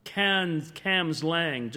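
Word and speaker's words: Cambuslang: a young man who saw revival at a place called Cams Lang, just outside Glasgow, or as we would spell it, Cambus Lang,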